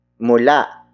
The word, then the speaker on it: mula: Word for word it's lumayo mula sa atin ang malas.